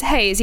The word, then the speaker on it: Heize: How do you pronounce her name? – Haze. – Haze, yeah, Haze. Haze, H-E-I-Z-E. Haze?